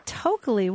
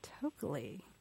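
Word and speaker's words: Tokeli: Tokalee, what a great name. How do you spell that? – It's T-O-K-E-L-I, Tokalee. – Tokalee.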